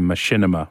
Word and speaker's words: Machinima: But your days when you were a content creator, and you look at, I went on and dabbled myself in Machinima Respawn, yep. That's how you say it, good.